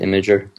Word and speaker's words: Imgur: Well, yeah, that site, I don't even know how you pronounce it, Imgur. – Yeah, that's right.